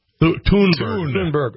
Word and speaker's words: Thunberg: Greta Thunberg. Is that how you say it? – Thunberg. – Okay. – A cartoon. – Greta Thunberg said, I always wondered what Wednesday Adams was up to.